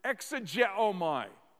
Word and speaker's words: exegeomai: And off to the side, write the word exegeomai. Exegeomai. E-X-E-G, and it's spelled for you right there. E-O-M-A-I.